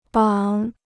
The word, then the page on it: 绑: bǎng